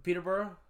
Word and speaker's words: Peterborough: Peterborough? – Peterborough.